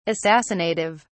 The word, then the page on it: assassinative: British English